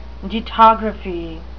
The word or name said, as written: dittography